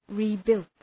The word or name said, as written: rebuilt